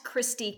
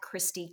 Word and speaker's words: Kristi: At Christy Cowboy, and that's spelled K-R-I-S-T-I, at Christy Cowboy.